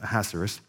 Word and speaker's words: Ahasuerus: I think the New King James, it's um, Ahasuerus, which is not actually the Hebrew pronunciation, but you guys don't want to hear me try the Hebrew pronunciation. So we're going to go with Ahasuerus.